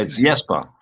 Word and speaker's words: Jesper: It's Jesper. It's Jesper. – It's Jesper. – It's Jesper. – Yeah, it's soft J. In Danish, J's are often soft.